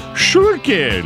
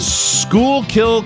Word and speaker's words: Schuylkill: Thank you for the pronouncer on that. Otherwise, I would have said Schuylkill. – Hello, Schuylkill. – Schuylkill County, Pennsylvania, and Budapest, Hungary.